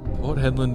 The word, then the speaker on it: Port Hedland: Port Hedland to Perth.